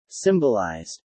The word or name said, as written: symbolized